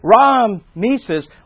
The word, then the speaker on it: Ramses: So Ra-Mises, or what we call Ramses, I think we got that from Cecil B. DeMille, but that pronunciation of Ramses, the second or third, whichever one it is, had a serpent on his turban.